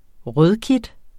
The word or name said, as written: rødkit